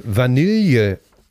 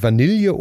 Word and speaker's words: Vanille: Und es heißt Vanille. Es wird natürlich äh, Vanille ausgesprochen in Deutschland, Vanille oder Vanille. Ja. Ne? Und äh, das kann man sich dann aussuchen. Aber zum Beispiel in Österreich sagt man, glaube ich, nur Vanille.